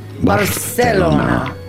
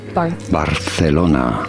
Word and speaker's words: Barcelona: You do want to pronounce the C a little bit like TH in English with your tongue between your teeth. Barcelona. – Barcelona. – Barcelona is how it is said in Spain.